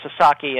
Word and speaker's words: Sasaki: And Joseph Sasaki, and I should make it clear, it's pronounced Sasaki, and Americans and Canadians sometimes have trouble with Japanese names, and it's very understandable.